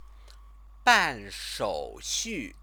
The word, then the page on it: banshouxu: chinese